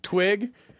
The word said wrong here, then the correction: Trig: No, that's Trig. – Oh. – Trig with an R. – Oh, Trig. – Maybe an R with a silent H and a J, but I think it's just T-R-I-G.